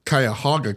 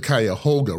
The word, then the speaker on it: Cuyahoga: Most natives will say Cuyahoga County, but we'll say the Cuyahoga River.